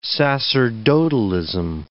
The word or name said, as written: sacerdotalism